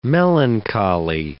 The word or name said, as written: melancholy